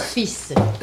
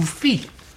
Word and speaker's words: fils: Pour fils. – Évidemment, c'est fils que l'on dit et que l'on... – Ou fille. – Ah, fille, c'est l'ancienne prononciation.